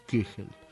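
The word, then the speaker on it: Köchel: La indicación K o KB corta, que se refiere a Kegel Verzeichnis, que es el catálogo de Kegel, que se escribe Kochel, con K, Kochel se escribe, pero se pronuncia Kegel. Diría aquí Ricardo, que con su alemán me diría Kegel.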